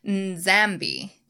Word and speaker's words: Nzambi: So this root helps form the names of several deities, including the Congo creator deity, Nzambi, which is N-Z-A-M-B-I, Mpungo. I feel like you do pronounce a little bit of the N and the M at the beginning of these words.